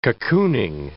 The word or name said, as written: cocooning